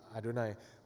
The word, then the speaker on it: Adonai: The, the Hebrew text is Yahweh, but how the Jews pronounce it is Adonai.